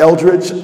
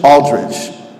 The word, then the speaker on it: Aldridge: Not Eldridge, Aldridge. Spell it phonetically and you'll get it right.